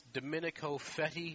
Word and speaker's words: Domenico Fetti: I believe it's pronounced Domenico Fetti. Is that even close? Good.